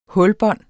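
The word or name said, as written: hulbånd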